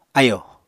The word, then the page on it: ayò: apatani